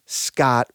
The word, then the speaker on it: skat: But the weird part is It has to do with another Scandinavian word, which is S-K-A-T, Scott, which meant taxes. And the British, again, took that word and started, you know, they pronounce it Scott.